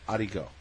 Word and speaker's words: ricotta: R-I-C-O-T-T-A is pronounced arigo. Because my grandmother, when she wanted rigotta, would say, I need some arigo. And so it became arigo. And so, to me, R-I-C-O-T-T-A is arigo.